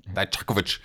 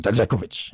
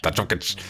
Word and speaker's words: Dijakovic: Ähm, Dijakovic. Ähm, aber und, und ich habe ja auch schon das etliche ein bisschen deutsch ausgesprochen. Dajakovic. Dajakovic.